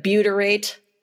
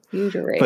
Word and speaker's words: butyrate: Am I saying that right? Like Gatorade, but butyrate? – I don't make the connection there necessarily, but okay. – It's good. Okay. – It sounds somewhat similar. – Butyrate.